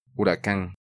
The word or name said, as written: Huracan